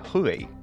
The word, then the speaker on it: Hubei: Has estado en, Hubei, que no se pronuncia Hubei, se pronuncia Hubei.